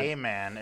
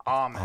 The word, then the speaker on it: amen: Think it's pronounced it's not, amen. It's amen.